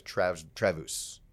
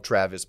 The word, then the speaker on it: Travus: His name is Travus. Friends call him Travis, but it's spelled T-R-A-V-U-S. His dad's German, whatever. I call him Travus because I think it sounds cooler than Travis.